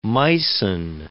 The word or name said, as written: Meissen